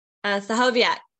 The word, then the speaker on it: Stachowiak: Stachowiak, but very, very close.